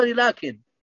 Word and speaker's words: لكن: لكن المد تنطق ولا تكتب. – طبعا ليست هناك فائدة، هذا هو النطق الصحيح بالنسبة لكن. هذا هو النطق الصحيح للكن.